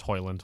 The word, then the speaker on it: Højlund: Yeah, they added Højlund. Did I say that right? Højlund? – It's Højlund.